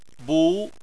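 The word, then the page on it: bu: tone 2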